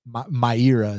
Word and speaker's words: Mayra: So for that reason, I'm picking Mayra. Mayra is actually how you pronounce it.